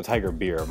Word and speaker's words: Tiger Beer: It's called Tiger Bear? – Yeah. – I like it. – No, Tiger Beer, my bad.